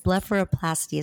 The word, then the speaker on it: blepharoplasty: I think that when you do the under eye procedure and fill in, do like a non-surgical blepharoplasty, is that correct? – Yeah.